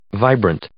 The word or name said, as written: vibrant